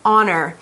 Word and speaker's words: honor: Now, it's important to note that honor does not have an H sound. Even though it's spelled with H, it's pronounced with a silent H, honor.